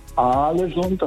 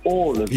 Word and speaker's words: Ålesund: Also das heißt nicht Alesund, das heißt Ålesund. – Ja, natürlich.